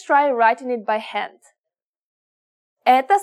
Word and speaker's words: Это: We read this Это собака. Remember how O can be pronounced as a soft A? In this case, we pronounce both O's as A.